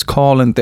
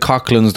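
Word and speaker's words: Coughlans: This week, I'm going to be doing a preview down in Collins in Cork by, I think it's Collins. Down in Cork, they pronounce it Collins. It looks like Cochlins the way it's spelled. It's C-O-U-G-H-L-A-N-S.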